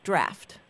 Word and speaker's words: draught: And anytime you see it spelled D-R-A-U-G-H-T, that's British. Although they always pronounce it draft.